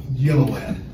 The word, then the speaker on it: Gilliland: Did I pronounce your last name correctly? – Yeah, that's close enough. Gilliland.